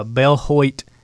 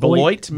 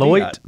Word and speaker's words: Beloit: Yeah, today's story comes from a uh, Bell Hoyt Ohio. Am I saying that right? Bel- Beloit, maybe. Beloit, Beloit, Ohio.